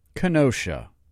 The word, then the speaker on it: Kenosha: The correct pronunciation is Kenosha. Kenosha.